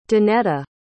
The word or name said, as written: danetta